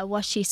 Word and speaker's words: washi: Is that washi paper or is that it's pronounced something else? – Yeah, washi. So it's washi. A Japanese word.